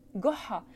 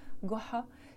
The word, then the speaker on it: Goha: Au Maroc et en Algérie, on dit Jaha ou Joha ou Goha chez les Égyptiens, par exemple, parce qu'ils prononcent le Ja en disant Ga, Goha.